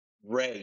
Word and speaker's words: Rea: Ray, Jamie Rea. So I sound like a country singer who just got a DUI.